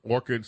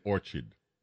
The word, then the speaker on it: Orchard Beach: Some people pronounce it Orchid, some say Orchard.